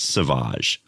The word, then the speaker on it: Sauvage: The centerpiece is Johnny Depp, and it's pronounced Sauvage.